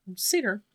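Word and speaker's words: seiðr: or how do you pronounce it? – Satyr magic. – Satyr? – Yeah. – Okay. – And that's S-E-I-D-R for those of you that are listening and curious.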